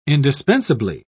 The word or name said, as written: indispensably